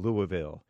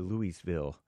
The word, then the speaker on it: Louisville: in Louisville or Louisville, if you're wrong, if you want to pronounce it wrong, I guess.